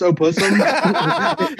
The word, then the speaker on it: opossum: You know yeah, did you know it's actually pronounced opussum? – Did you know it's just that's how they used to say it in the old days. – You know, it's just pussum, the O silent.